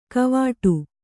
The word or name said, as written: ಗವೇಡು